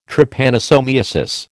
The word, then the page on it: Trypanosomiasis: American English